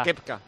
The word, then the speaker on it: Koepka: O sea, se pronuncia Koepka. – Los Yankees dicen Koepka, porque la O es junta una Koepka.